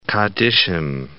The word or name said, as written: kaddishim